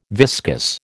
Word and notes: viscous: American English

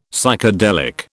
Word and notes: Psychedelic: British English

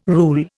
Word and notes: rule: Indian

